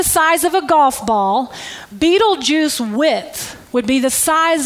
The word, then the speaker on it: Betelgeuse: It's spelled differently than it sounds, but it's called Betelgeuse.